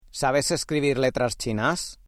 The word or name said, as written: letras chinas